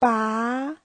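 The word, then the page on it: 拔: ba2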